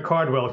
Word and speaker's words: Cardwell: So, I'm going to change my name to Cardwell Cabral. – Make sure they spell it correctly. – Yeah, exactly. – A lot of people want to make it Caldwell, C-A-L-D, as opposed to C-A-R-D.